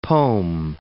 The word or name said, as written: pome